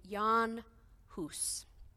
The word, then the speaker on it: Jan Hus: But one idea is that he was inspired by a 15th century Catholic priest named Jan Hus. It's spelled J-A-N, Jan, H-U-S, Hus.